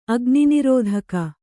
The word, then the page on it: ಅಗ್ನಿನಿರೋಧಕ: kannada